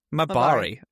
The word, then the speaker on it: Mabari: Seems like you're Marabai? Oh my god, how do you say that? It's Marabai? – Mabari.